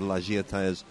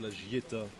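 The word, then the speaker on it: La Giettaz: Chalet in the beautiful sort of village of La Gietaz. – I think it's La Gieta. – La Gieta. – Yeah, you don't pronounce the Z in those places.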